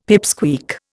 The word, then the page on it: pipsqueak: Indian